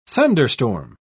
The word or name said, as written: thunderstorm